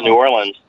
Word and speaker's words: New Orleans: And when I first went to New Orleans, I realized that I was pronouncing it wrong. It's called New Orleans.